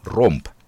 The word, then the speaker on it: Romp: Tämä on 16 Horse Power, Prisons Hoe Ramp, Romp, ei Ramp, ja Sack Lot and Ashes on levyn nimi.